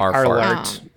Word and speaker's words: Arlert: I think is how you pronounce that. – Alert. – I think the R rolls dramatic. And it's kind of silent. Yeah. – It's like Arlert. Like, yeah, something like that.